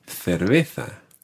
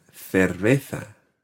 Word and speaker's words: cerveza: Now, this is a word that is definitely pronounced differently in different countries. You might hear una cerveza. – Una cerveza. – And that would be a Latin American pronunciation in certain countries. Cerveza. – Cerveza. – In Spain, Cerveza.